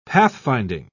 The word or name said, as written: pathfinding